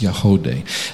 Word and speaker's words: Yagody: Y-A-G-O-D-Y, but it's pronounced Yahode.